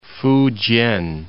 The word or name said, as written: Fujian